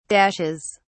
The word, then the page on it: dashes: British English